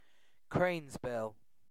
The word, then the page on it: cranesbill: British English